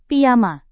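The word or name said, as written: pkk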